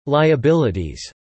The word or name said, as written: liabilities